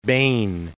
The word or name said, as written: Bain